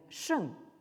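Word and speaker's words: sheng (圣): dans ce caractère qui se prononce en chinois moderne sheng, hein, au quatrième ton, et qui désigne donc le sage ou la sagesse.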